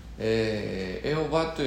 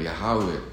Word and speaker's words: ヤハウェ: あたかもこれが神の名前のように使われてますけども、エホバというのは本当はこれは間違った発音であって、正しくはこれヤハウェという、まあ、ヤハウェの神、まあイスラエルの人々はそういうふうに呼んだわけですね。ヤハウェの神、